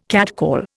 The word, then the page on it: catcall: Indian